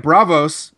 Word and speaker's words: Braavos: Braavos to the House of Black and White. Am I saying that correctly? Do we know yep.